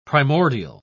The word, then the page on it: primordia: British English